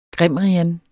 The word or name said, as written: grimrian